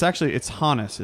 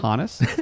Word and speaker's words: Honus: But it's Honus is actually how you pronounce that name. Just a heads up. – All right. – Honus.